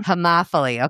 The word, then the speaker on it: homophily: I would just say in regular English, people who are like you, people who are homophily.